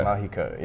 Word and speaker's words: Magico: This is the Telecaster Mahico. – Mahico. – Mahico, yes. – Mahico. It's spelled M-A-G-I-C-O.